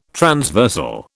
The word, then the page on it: transversal: British English